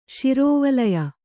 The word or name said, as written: ಶಿರೋವಲಯ